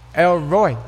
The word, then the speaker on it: El Roi: El Roi.